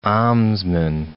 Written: almsman

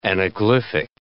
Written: anaglyphic

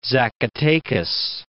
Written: Zacatecas

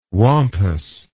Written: wampus

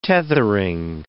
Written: tethering

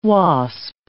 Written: wasp

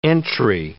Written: entry

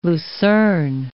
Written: lucerne

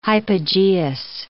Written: hypogeous

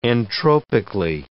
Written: entropically

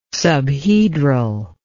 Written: subhedral

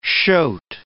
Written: shoat

